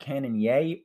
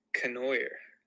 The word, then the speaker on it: Cannonier: So moving on to next weekend, we got Sean Strickland versus Jared Cannonier, or is it Cannonier? Which one are we going to choose here? – I think it's Cannonier.